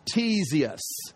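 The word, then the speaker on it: Ctesias: This is pronounced Tesius, just like T-E-S, the C is silent.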